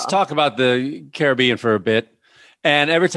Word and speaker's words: Caribbean: Some islands call it Caribbean, but most islands the pronunciation is Caribbean.